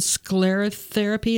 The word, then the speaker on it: sclerotherapy: So you mentioned sclerotherapy. Is that how you pronounce that? – Correct.